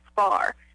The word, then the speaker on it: Spar: But my last name is Spar, and it's actually spelled like how you would spar in karate.